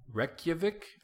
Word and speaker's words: Reykjavik: Reykjavik, I believe is how it's pronounced.